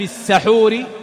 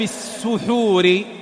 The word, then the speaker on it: السحور: ومن المسائل أيضا، إن قلت هل النطق الصحيح في الحديث أن يقال فإن في السحور بفتح السين أو فإن في السحور بضمها؟ فأقول كلاهما صحيح، والرواية نقلت عن أهل الحديث والرواة بإثباتهما بفتح السين وضمها.